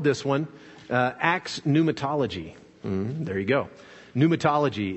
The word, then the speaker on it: Pneumatology: Pneumatology spelled with a P, P N E U M A T O L O G Y and uh,